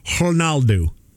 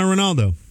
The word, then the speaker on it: Ronaldo: But apparently, to say Cristiano Ronaldo correctly, you say it like this. Cristiano Ronaldo. That's it. The R sounds like an H. The D-O sounds like do. Ronaldo.